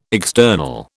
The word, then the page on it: external: British English